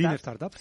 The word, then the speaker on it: Lean Startup: Lean Startup, sí.